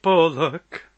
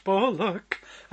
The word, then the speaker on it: Bullock: Stuart Bullock. My name is Stuart Bullock, and nothing rhymes with bullock, but bollocks close, bollocks close.